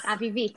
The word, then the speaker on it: Avivit: Avivit.